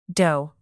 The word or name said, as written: doe